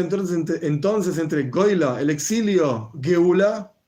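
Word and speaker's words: geula (גאולה): La palabra redención es geula.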